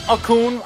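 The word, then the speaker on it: Okung: Okun. I hate these guys' names because their G is silent or the OU is silent.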